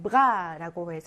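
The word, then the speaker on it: bras: 자, 1번에 bras라고 해서 팔이라고 하는 단어인데 이것이 발음이 되지 않고 있네요. 그렇죠? bras라고 하지 않죠?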